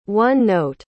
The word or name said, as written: one-note